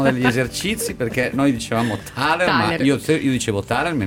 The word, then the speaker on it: Thaler: C'è stato un grande dibattito sulla pronuncia di questo cognome, ma l'Accademia di Svezia dice Thaler, quindi Thaler.